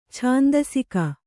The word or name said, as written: ಛಂದಸಿಗ